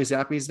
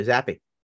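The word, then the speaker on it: Zappe: And I learned how to pronounce Bailey Zappi's name. – Bailey Zappi. – I always thought it was Zapp. – Nope. Zappi.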